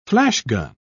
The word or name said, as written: flashgun